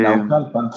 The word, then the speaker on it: Naucalpan: Naucalpan, lo estoy pronunciando bien. Eh, Naucalpan, sí. – Naucalpan